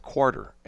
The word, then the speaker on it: quarter: And I got to say that properly because my Kansas City friends were saying, I can't believe you people in St. Louis say quarter. Where's the R? There's two R's in there.